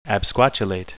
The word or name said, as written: absquatulate